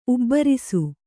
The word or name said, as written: ಉಬ್ಬರಿಸು